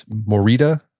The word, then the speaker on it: Morita: It's Morita therapy.